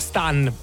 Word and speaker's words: Stan: Tra l'altro canzoni che molti ricordano perché c'erano, anno 2011, Mr. Saxo Beat di Alexandra Stan, un'altra Stan, anche perché questa è la pronuncia corretta, non essendo lei inglese, altrimenti sarebbe stato abbastanza chiaro e canzoni.